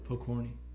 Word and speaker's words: Pokorny: It's spelled P-O-K-O-R-N-Y. How'd you do that? – Pokorny.